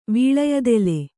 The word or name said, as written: ವೀಳೆಯದೆಲೆ